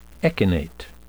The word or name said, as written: echinate